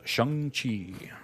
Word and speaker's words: Shang-Chi: Because in some areas of Asia, you would say Sheng. That's how you pronounce it. But where the character is from in the movie in China, he would pronounce it as Shang-Chi instead of Shang-Chi. It was a big hubbub that happened recently where suddenly people want you to pronounce things with the right inflection depending on where it comes from. And so it's Shang-Chi.